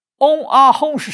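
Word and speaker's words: 嗡啊吽: 这三个字发音叫嗡啊哄，嗡啊哄是什么意思呢？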